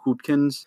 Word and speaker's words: Hootkins: And I think of the three movies that I just mentioned with this particular actor, William Hootkins, if I'm pronouncing his name right.